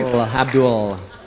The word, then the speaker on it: Abdul: That's how it's pronounced. – Holds on to the L a little too long. – Oh, does he? – Abdul.